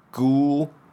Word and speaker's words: ghoul: The way that you can think of it is ghoul, ghoul. Two syllables, ghoul, ghoul. It's interesting because it almost, the O-U-L might be,